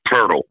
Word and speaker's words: Poeltl: Jakob Poeltl. – Jakob. – There's no R in Poeltl, by the way.